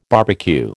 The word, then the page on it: barbecue: American English